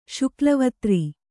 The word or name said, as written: ಶುಕ್ಲವತ್ರಿ